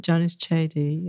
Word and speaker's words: Janice Cheddie: So I would just like to present our speaker today, or our guest, Janice Cheddie, or as you were saying, you have another pronunciation. – Yeah, so my mother pronounced it Janice Cheddie, yeah.